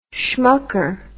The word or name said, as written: schmucker